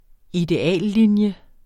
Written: ideallinje

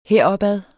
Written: heropad

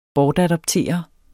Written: bortadoptere

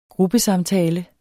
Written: gruppesamtale

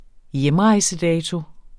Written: hjemrejsedato

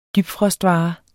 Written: dybfrostvare

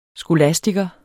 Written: skolastiker

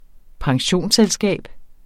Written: pensionsselskab

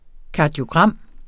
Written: kardiogram

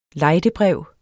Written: lejdebrev

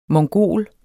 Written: mongol

